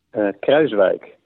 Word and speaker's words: Kruiswijk: Kruiswijk.